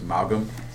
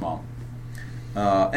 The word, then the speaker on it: Maugham: I used to pronounce his name wrong. I thought it was Maugham. – Yeah. – It's not. – It's Maugham. – It's Maugham. Anyway, he's an English author who is prolific.